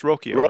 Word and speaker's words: Rocchio: Well, before I came to spring training and before that began, it probably would have been Rocaccio. I hope I'm saying that right. Rocaccio, who played at Double A. – Is it Rocchio? It's Rocchio, right? – Rocchio. I'm sorry. Yeah, Rocchio. Okay.